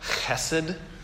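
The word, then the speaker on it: chesed (חסד): But the Hebrew word is hesed. Apparently, if you pronounce it right, it's more like chesed.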